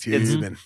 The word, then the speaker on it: Toobin: I don't know why it's funny that Tubin is spelled T-O-O-B-I-N. – Hilarious name. – It's Tubin.